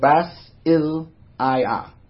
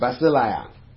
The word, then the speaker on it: basileia: The Greek word, I'm just going to pronounce it. I'm not going to try and spell it. It's called basileia. So it's basileia, which refers to rule.